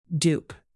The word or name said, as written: dupe